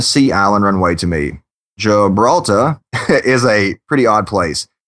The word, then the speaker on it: Gibraltar: And we got some feedback from Richard that says, regarding China's island airstrip, Britain has done a very similar thing in Gibraltar. Sorry about that. – Gibraltar, it's called. – Yeah, could not pronounce that for anything.